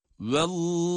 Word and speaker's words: vallahu: Bu defa Allah derken oradaki şeddeli lam da kalın okunur. Şayet o önceki harf ince harflerden ise o ince okunur, şeddeli lam kalın okunur. Bakalım. Burada olduğu gibi. Vallahu, vallahu.